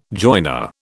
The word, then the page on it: joiner: British English